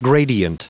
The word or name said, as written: gradient